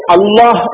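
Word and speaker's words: അള്ളാഹ് (الله): ഇത് രണ്ടുമല്ലാത്ത ഒരു ഉച്ചാരണമാണ് നമുക്ക് അള്ളാഹ് എന്ന് പറയുമ്പോൾ ഉച്ചരിക്കാൻ കഴിയേണ്ടത്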